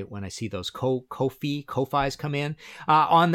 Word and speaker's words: Ko-fi: Uh, if you like this episode and want to uh, support future episodes, you can go to Ko-fi rhymes with no fee uh, dot com slash Martin Popoff and hit the red support button.